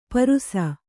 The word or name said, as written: ಪರುಸ